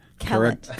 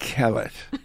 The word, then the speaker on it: Kellett: how do you pronounce Colette? – Kellett. Kellett, like pellet. – Kellett.